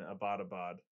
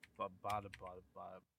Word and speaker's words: Abbottabad: How you say it? Abbottabad, whatever it is. – Abbottabad.